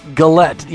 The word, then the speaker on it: Gullett: Gullett. – Yeah. See, I would have done. I would have said. Yeah, I would have said it the opposite way with the emphasis on the different syllable.